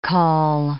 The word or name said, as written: caul